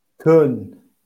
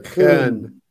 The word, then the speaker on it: Köln: Köln. – Köln. – You got to get the, like, the back of the throat L in there. Köln.